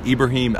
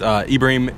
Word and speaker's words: Ibraheem: You can find me online, just R-E-L-E-N-T-L-E-S-S, and then F-O-R. And then Success, S-U-C-C-E-S-S. Or just under my name, Ibrahim Elglad. Either one of those. Ibrahim is spelled I-B-R-A-H-E-E-M, as in Marine. And then Elglad, E-L-G-A-L-L-A-D, as in Delta.